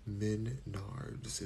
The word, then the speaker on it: Menards: You said Menards. Menards is how you pronounce it.